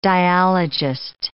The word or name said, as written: dialogist